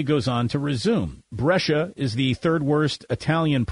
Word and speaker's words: Brescia: Brescia? Did I say that correctly? – Brescia. – Brescia. Thank you very much. It's like Patricia, CIA. Brescia.